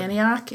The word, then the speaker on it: Antioch: But um, they they recorded is it Antioch? Is that how you say it? Antioch, Antioch.